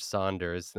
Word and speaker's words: Sanders: I mean, and I said George Sanders last week, like such a boob, but it's George Saunders. And you were so kind not to correct me, but you did pronounce it correctly.